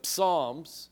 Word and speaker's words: Psalms: Now, the songs, if you know about the psalms, it starts with a P. Most people don't pronounce the P.